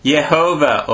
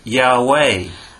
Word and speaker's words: Yahweh: The traditionally accepted way of pronouncing this name was Jehovah, or in English, Jehovah. – 伝統的にこの、 YHWH これは、エホバと発音、英語ではジェホバになりますが、エホバと発音されてきたんです。 – But nowadays, many scholars think that this word might have been pronounced Yahweh.